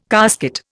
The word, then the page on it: casket: Indian